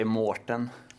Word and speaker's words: Maurten: Säger man Mårten eller Maurten? – Jag säger Mårten. – Okej, okay. mm. men det är inte Mårten med Å, även om det är så man uttalar det? – Ja, men exakt.